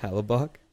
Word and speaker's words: Hellebuyck: Helichuk. Well, how do you pronounce that? Hellebuyck. Hellebuyck.